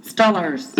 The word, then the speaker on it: Steelers: Like the Steelers. Stullers. Gotta say it like that, Andrew.